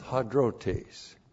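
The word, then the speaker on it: hadrotes (ἁδρότης): H-A-D-R-O-T, long E-S, hadrotes.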